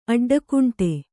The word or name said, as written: ಅಡ್ಡಕುಂಟೆ